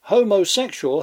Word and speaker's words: homosexual: Homosexual has four syllables, not five. Professor Daniel Jones, in his classic Outline of English Phonetics, lists the sound represented by that UA as a diphthong, not two separate vowels.